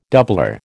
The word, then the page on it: doublure: American English